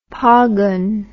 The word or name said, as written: phagun